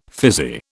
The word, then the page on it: fizzy: British English